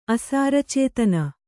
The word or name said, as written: ಅಸಾರಚೇತನ